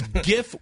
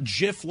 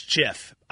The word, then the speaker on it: GIF: It's GIF with the hard G, not JIF like the peanut butter. So I've been going on false information. – You're on bad intel. It's JIF.